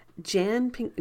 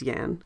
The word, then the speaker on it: Jan: It is directed by Jan Pinkava. I want to say Jan because that's my name, but he is a man, and generally when men are named Jan, it's pronounced Yan.